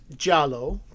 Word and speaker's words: giallo: So in Italian cinema, giallo, pronounced giallo,